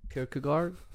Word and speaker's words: Kierkegaard: kierkegaard Thank you.